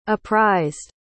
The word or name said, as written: apprised